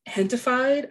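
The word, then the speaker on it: Gentefied: I am excited for America Ferrera's show, Hentified. I think that's how you pronounce it, but it's like gentified. It's spelled gentified, but I think it's pronounced hentified.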